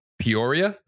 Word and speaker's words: Peoria: Peoria? Did I say that right? – Yes.